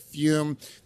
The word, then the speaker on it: Fume: That is spelled F-U-M, pronounced Fume.